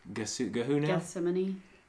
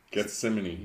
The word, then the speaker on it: Gethsemane: Gethsemane. – Gethsemane. – Gethsemane.